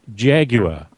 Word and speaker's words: Jaguar: And Jeremy Clarkson said it's Jaguar, how it is spelt.